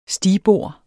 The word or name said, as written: STIGBORDENES